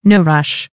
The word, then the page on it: no rush: American English